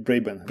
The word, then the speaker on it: Braben: przestały się liczyć, jak tam David Bra Bra Braben, dobrze mówię? Braden? – Tak, David, David Braben. Braben. Tak, Braben. Pisze się Braben, na tak, pewno.